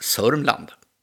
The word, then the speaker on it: Sörmland: Idag har jag den stora äran att få samtala om märkliga händelser i landskapet Södermanland, som vanligare kanske uttalas Sörmland.